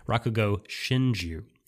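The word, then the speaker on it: Rakugo Shinjū: Since you brought up Showa Genroku Rakugo Shinjo, I totally probably mispronounced it. Rakugo Shinju.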